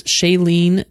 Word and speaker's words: Shailene: It stars Shailene, did I say that right? – Yes.